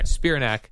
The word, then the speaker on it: Spiranak: How do you say her last Spiranak. – Name? Spiranak. – Yes.